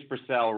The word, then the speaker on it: Brassell: It's Brassell, Rory, but don't worry about it.